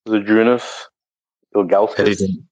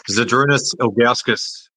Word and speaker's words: Zydrunas Ilgauskas: Zydrunas Ilgauskas. – Well done, mate. Yeah, great pronunciation too. Zydrunas Ilgauskas.